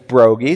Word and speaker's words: Broggi: That would be like, well, my last name is Brogi, 6 letters, B-R-O-G-G-I.